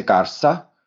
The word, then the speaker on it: Garza: So eh, or Garza, you have to say Garza. Really, so you're speaking English. If you're speaking English, then you use the English pronunciation.